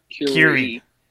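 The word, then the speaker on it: Kure: It's going to be really difficult, but he wanted to Zoom in from, is it Cure or Curry Beach? How do you say that word? – Curie. – Curie. Curie. All right, it's spelled like cure with a K.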